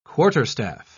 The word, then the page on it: quarterstaff: British English